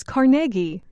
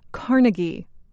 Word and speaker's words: Carnegie: It is Carnegie, not Carnegie. The stress is on the middle syllable.